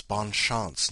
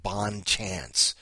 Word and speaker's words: Bonne Chance: Now it's Bonne Chance, not Bonne Chance. You heathens should know how to pronounce things.